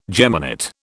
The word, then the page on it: geminate: British English